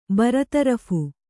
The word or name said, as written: ಬರತರಫ್